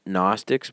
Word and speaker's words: Gnostic: It's spelled G-N-O-S-T-I-C, Gnostic. So it's got a silent G.